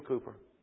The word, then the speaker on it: Cowper: His last name is Cooper. It's spelled Cowper, C-O-W-P-E-R. I think his first name's William. William Cooper.